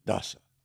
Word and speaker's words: Dassa: So a big shout out to Dr. Gabe Dasa. Dasa. D-A-S-S-A. – D-A-S-S-A. – I think I said it right.